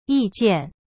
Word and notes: 意见: yi jian

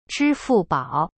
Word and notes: 支付宝: zhi fu bao

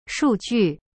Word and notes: 数据: shu ju

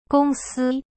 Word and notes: 公司: gong si

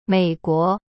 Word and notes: 美国: mei-guo

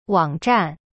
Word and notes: 网站: wang zhan

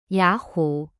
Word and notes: 雅虎: ya hu

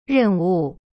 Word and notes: 任务: ren wu

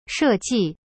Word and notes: 设计: she ji

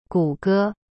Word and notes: 谷歌: gu ge

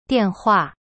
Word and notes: 电话: dian hua

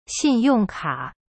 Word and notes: 信用卡: xin yong ka

